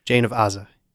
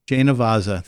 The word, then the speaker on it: Jane of Aza: And when we come back, we'll explain a little bit about St. Dominic's mom, St. Jan of Az. I think I said it right. Az? – Jane of Aza. – Jane of Aza.